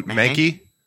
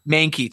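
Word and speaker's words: Mahnke: And then I hope I don't butcher the names, but the artist is Doug Mahanke. – Oh, Mankey? – Mankey, thank you.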